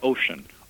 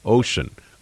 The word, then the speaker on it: Ossian: They pronounce it Ossian. – Ossian.